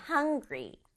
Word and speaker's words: hungry: Hungry. Hungry. 발음할 때는 hung 부분이 강조해요.